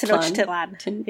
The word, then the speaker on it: Tenochtitlan: Apologize to all of the very nice people who have tried to teach me to pronounce that correctly. – Yes, I believe it's Tenochtitlan.